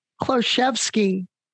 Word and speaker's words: Kloszewski: Kloszewski. – Kloszewski. I never would have said it that way. So I'm so glad you helped me with that introduction. – It's not pronounced like it's spelled.